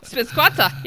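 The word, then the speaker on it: SPEBSQSA: How do you pronounce that? – Spibisquassa. – Yes.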